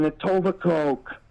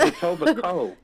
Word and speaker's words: Etobicoke: You say it like that because you're not from here. – That's what makes it funny. – I know, and I say it wrong on purpose, and it's great. It's Etobicoke.